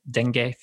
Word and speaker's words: dengue: Am I saying that right? Dengue fever? – Yeah, multiple pronunciations are acceptable, but we'll absolutely accept that one.